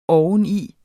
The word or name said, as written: oveni